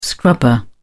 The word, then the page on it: scrubber: British English